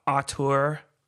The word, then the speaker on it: auteur: I'm going to go make some auteur software. Is that how you say it? – You're the pronunciation expert. Auteur.